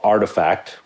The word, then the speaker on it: RTFKT: Yeah, I linked up with a group called Artifact, which is spelled R-T-F-K-T, okay?